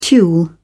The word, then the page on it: tulle: British English